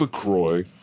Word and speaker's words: LaCroix: LaCroix? – It's LaCroix.